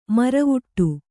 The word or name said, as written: ಮುರವಿಡು